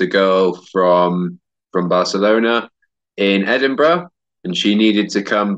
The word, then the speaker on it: Edinburgh: Edinburgh is one of those places that's very difficult to pronounce because everybody wants to say Edinburgh, but it's not very logically written. So Edinburgh. Yeah, I studied biomedical science,